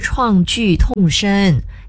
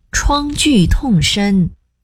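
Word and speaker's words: 创巨痛深: 你想想，有幸活下来的人回想那段历史，创剧痛深，永远也忘不了。 – 不是创剧痛深，得读作第一声创，创剧痛深。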